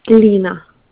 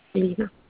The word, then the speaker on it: Lena: Lena. – Can you spell that for me? – L-E-L like Larry, E, N like Nancy, A. Lena. – L-E-A? – L-E-N like Nancy, A.